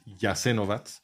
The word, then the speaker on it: Jasenovac: Si lo ves escrito en alfabeto latino, parece que dice Jasenovac, pero la pronunciación es Jasenovac.